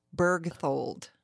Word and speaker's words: Berchtold: You did not, but that's okay. – Say it. – Laura Berchtold. – It's a hard name. – So there's no you. – There is no you.